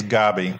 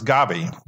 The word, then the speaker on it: Gaby: My name is Gabby, like Bobby, which is short for Robert, but my name is Gabby, but with a G.